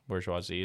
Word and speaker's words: bourgeoisie: Bourgeoisie.